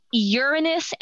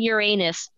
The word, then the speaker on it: Uranus: There are two official ways of pronouncing it. And I know this because I researched it. I looked it up in like astronomy society. I looked it up on NASA. Uranus and Uranus are both correct pronunciations.